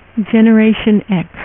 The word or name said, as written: generation x